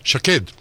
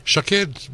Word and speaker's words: Shaked: Shaked. – Shaked. – Shaked rhymes with the head. It means almond tree in Hebrew.